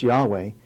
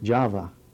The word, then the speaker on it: Yahweh: as Yahweh. It's more like Java. They didn't have the J sound.